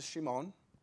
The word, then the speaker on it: Shimon: Simeon, the way it's pronounced is Shimon, which means to hear.